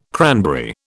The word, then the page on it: cranberry: British English